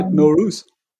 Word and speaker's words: Nowruz: Nowruz, right? – Yes, that's right, Nowruz.